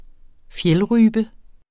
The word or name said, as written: fjeldrype